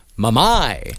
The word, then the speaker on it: mummy: She would cry and scream the word mummy over and over again, except she pronounced it mamai.